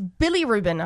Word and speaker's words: bilirubin: It's bilirubin.